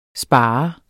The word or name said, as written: SPARERE